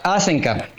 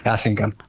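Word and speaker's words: Hasenkamp: Asenca. – Asenca. Asenca. – Claro. Digamos, la pronunciación original sería de Asenca, porque sí. Es de origen alemán. Sí. Pero así, coloquialmente, Asenca. – Asenca.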